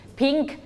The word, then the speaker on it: pink: Pink. – สังเกตดีๆลงท้ายด้วย letter K ออกเสียงให้ชัดเจน pink.